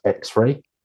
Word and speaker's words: XRAI: And in case you're wondering, that stands for Extended Reality Artificial Intelligence and is pronounced X-ray.